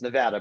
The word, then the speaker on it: Nevada: You're already in the doghouse if you said the name wrong, but that's okay. It's Nevada, but fine. There you go. – I'm an Illinois guy. So okay, I hear you, but if you don't say it right here, they get pissed off.